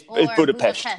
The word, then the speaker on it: Budapest: It's Budapest. Budapest is the right way to say it, but okay. More commonly around the world, it's called Budapest.